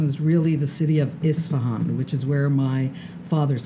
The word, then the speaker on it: Isfahan: Isfahan.